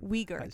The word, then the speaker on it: Uyghur: Uyghur. That's how you say it.